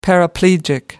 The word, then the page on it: paraplegic: American English